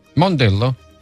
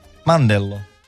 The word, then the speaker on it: Mandello: Mondello? – Mandello. – Non si dice Mondello? Ah, Licco. – Ah, ma lì, Mandello.